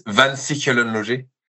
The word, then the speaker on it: Van Sichelen-Loger: So it's VSL, but my last name is Van Sichelen-Loger. So like it's like V is Van, S Sichelen-Loger, F O L.